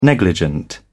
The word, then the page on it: negligent: British English